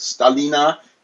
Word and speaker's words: Stalino: If you looked on the old Soviet maps, it would read Stalino, right? But Stalina in the proper pronunciation.